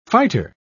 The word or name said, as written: fighter